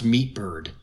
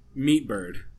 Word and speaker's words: MTBRD: Oh, the guy goes by, it's MTBRD, but I think he says it's pronounced Meatbird. – Meatbird.